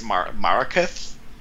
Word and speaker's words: Marakith: So a young man named Shion is killed protecting a magical spring of immortality, and because the villain, whose name is it's like Marcus, pronounced really badly. It's Marakith.